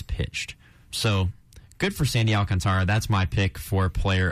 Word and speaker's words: Alcantara: I think it's Alcantara. It might have more of like a Spanish pronunciation, but let's just roll with Alcantara.